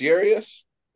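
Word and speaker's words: Urías: I always say his last name wrong. Urias? Is that how you say it? – I think it's Urias. Urias.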